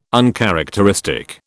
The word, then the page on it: uncharacteristic: British English